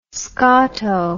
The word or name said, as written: scoto